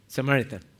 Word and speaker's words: Samaritan: Everybody's listened to and I'm going to butcher this one, the Samaritan woman. Yeah. Samaritan, thank you.